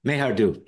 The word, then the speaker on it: Mayhardu: Mayhardu. Mayhardu. Hit the first syllable.